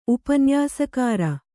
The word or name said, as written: ಉಪನ್ಯಾಸಕಾರ